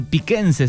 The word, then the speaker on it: piquense: Bien, piquense se dice, ¿no? De Pico, ¿está bien? ¿Lo dije bien? – Piquense, piquense, Bien, me, muy había, bien.